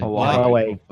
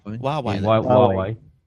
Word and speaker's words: Huawei: Huawei. – Huawei.